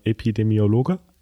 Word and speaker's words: Epidemiologe: Ich meine, du bist kein, äh, jetzt kommt ein Wort, was ich auch nicht aussprechen kann, ähm, Epidemiologe. – Ist das so? Das ist aber richtig ausgesprochen.